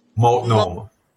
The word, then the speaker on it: Multnomah: Multnomah.